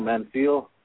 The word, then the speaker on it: Manziel: How do you say his name? Manziel? Johnny Manziel. – Yeah.